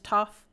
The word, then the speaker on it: Toph: Her name's Toph. It's a... – How do you spell it? – T-O-P-H. – Oh, nice. The P is silent.